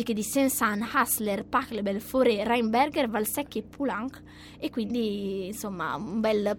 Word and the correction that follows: Poulenc: Ho sbagliato la pronuncia, no! Dai! – Poulenc. – Scusa, dai. – Anche se di solito la consonante vicina finale dei cognomi francesi si pronuncia sempre.